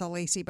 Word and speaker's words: Alesii: And because most people don't know how to spell my last name or pronounce it, it's Alesii, by the way.